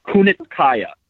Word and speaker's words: Kunitskaya: Kunitskaya. That's that's how I am like 99% sure it's pronounced.